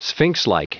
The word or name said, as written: sphinxlike